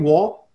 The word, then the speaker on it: Wohl: Is that how you say your last name, Wohl? – Yeah.